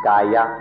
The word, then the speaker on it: kaya: The first stage of practice is called kayanupassana, which means contemplation of the kaya or body. When we speak of body or kaya here, it or in Thai it would be pronounced gaya.